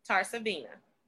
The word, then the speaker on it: Tarsavena: Delendra, which is D-E-L-E-N-D-R-A. Tarsavina, T-A-R-S-A-V-E-N-A.